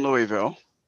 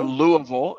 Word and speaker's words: Louisville: I'm in back in Louisville. – Back in Louisville. – Or Louisville, as the natives call it.